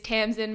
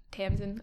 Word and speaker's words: Tamsyn: It'll be like, but the way to pronounce it is Tamsyn, Moana, Veale. So like Tamsyn as normal, Moana, kind of like the movie, and then Veale, like the meat.